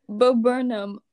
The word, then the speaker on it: Bo Burnham: I've known who Bro Burnham. You can't pronounce his name. Suddenly, makes me want to believe. – Bo Burnham, I have known about Bo Burnham since.